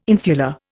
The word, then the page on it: infula: Indian